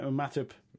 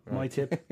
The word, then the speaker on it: Matip: Yeah, Matip. – Matip. – Yeah, my tip.